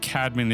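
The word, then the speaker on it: Cædmon: Someone pronounced it Cadman, but from what I've researched, Cadman is actually the proper way to say it.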